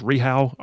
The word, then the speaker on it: Rehau: German auto supplier, I think it's pronounced Rehau, R-E-H-A-U, Rehau out of Coleman, announced a $50 million expansion creating 125 new jobs.